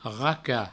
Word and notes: racar: provençal; raca

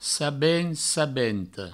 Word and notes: sabent, sabenta: sabent, sabento

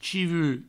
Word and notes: chivu: provençal

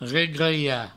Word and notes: regrelhar: regreia

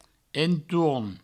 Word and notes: entorn: entour; provençal

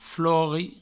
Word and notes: flori: Provençal

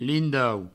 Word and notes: lindau: provençal

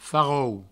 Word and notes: farrol: Provençal; farrou

